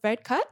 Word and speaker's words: Varadkar: Boris Johnson met with Leo Varadkar.